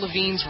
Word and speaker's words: Lavigne: It's spelled L-A-V-I-G-N-E.